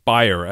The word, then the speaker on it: Bayer: He started a new role last year as Global Head of Digital Health Incubation and Innovation at Bayer, or Bayer as it's pronounced in Europe.